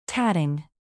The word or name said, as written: tatting